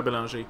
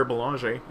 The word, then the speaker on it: Bélanger: So, but I worked really hard to do that, and when I say my name, like, it's Pierre Bélanger, and if I say Pierre Bélanger, and, like, they go, what, Pierre? – Bélanger. – Gare? What, Gare? – Bélanger. – Oh, Bélanger.